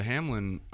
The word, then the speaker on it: Hamlin: It can, but like what happened to Hamlin?